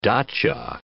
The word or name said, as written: dacha